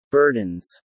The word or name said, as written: burdens